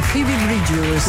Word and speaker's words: Phoebe Bridgers: noi Phoebe Bridgers. Come lo direbbe un perugino, l'inglese perugino, Phoebe Bridgers. Come? Con la patata in la bocca. – È giusto, capito? – È giusto, la pronuncia è corretta.